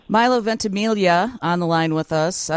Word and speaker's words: Ventimiglia: Wait, I just told Wendy, Milo, that I went to school with a kid named Harry Ventimiglia, but he pronounced the G. I know you don't, but could that be a relative?